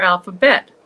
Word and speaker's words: Bette: We have the author of the newest book that just came out September 5th, 2023, Alpha Betty. Did I that pronounce correctly? Alphabetty? – That's a good question. I tend to call her Alpha Bette.